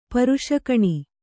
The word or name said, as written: ಪುರುಷಗುಣ